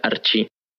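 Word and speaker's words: archi (ἀρχή): Y miren, mejor primero hablemos de el principio o el arjé, que pues vienen del griego y se pronuncia archi.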